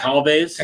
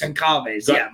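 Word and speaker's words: Gonçalves: Goncalves. – Goncalves.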